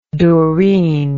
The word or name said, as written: dourine